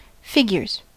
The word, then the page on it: figures: American English; /ˈfɪɡ.jɚz/